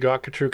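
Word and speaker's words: Gottgetreu: It's going to be Gotkatru Consulting.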